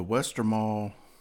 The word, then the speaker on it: Westmalle: Um, the Westmalle. That's how you pronounce it.